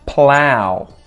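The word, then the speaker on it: plough: P-p-p. Impetuous. She made an impetuous decision. Impetuous. 55. Plow. Plow. Another GH that is silent.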